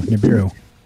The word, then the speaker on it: Nibiru: I'm not saying it right, am I? – Nibiru. – New beer. Yeah, beer, right. Ash taught me.